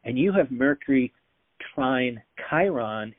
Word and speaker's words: Chiron: I know you said Chiron, but I usually call it it's C H I R O N. Okay. I R O N. But it's really a K sound.